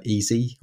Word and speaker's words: Eze: You know, you could say the same about Ings, but the player that I like is Easy, or EZE as it's spelled, at QPR.